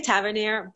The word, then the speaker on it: Tavernier: I say Tavernier, but that's the actual French way, the way you say okay.